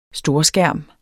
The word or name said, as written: storskærm